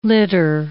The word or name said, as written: litter